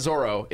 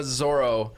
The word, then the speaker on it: Zoro: And it's not Zoro, it's Zoro.